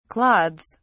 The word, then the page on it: clods: British English